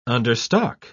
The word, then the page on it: understock: British English